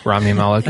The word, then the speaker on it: Rami Malek: Rami Malek, yeah.